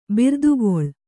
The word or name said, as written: ಬಿರ್ದುಗೊಳ್